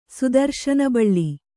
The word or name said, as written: ಸುದರ್ಶನಬಳ್ಳಿ